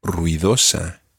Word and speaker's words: ruidosa: And the word for noisy in Spanish is ruidosa. – Ruidosa. – Ruidosa. – Ruidosa. – Ruidosa. – Ruidosa. – Sí. There are four syllables in that word. Ru-i-do-sa.